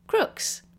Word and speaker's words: Crooks: And the epic music you're now hearing is by Raphael Crooks. Rhymes with books.